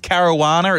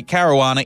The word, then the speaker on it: Caruana: It's Caruana. It's from Malta. And the way I get around people mispronouncing it, I say Caruana. It rhymes with marijuana.